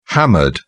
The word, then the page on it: hammered: British English